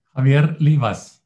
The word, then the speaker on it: Javier Livas: And I hope I'm pronouncing your name okay. – Javier Livas. – Yeah. Oh, Livas. Okay.